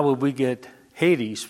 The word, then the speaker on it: Hades: But that would be pronounced Hades. The thing is, though, that in the Greek, there is no H. There's a way that they get the H pronounced, though, because they put a rough breathing mark in front of that first vowel.